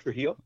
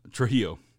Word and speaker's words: Trujillo: I mean, that's why I can't remember, is it Torello? Was that how you pronounce his last name? – Rob Trujillo. – Trujillo. – Yeah.